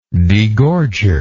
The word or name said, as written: degorger